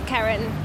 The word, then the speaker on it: Karin: It's Karen. – How do you spell it? – K-A-R-I-N.